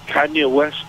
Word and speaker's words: Kanye West: Kanye West, is that how you pronounce his name? – Yes.